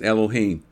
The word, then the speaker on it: Elohim: The Hebrew name for God in Genesis 1, 1 and 2 is Elohim. That's spelled E-L-O-H-I-M.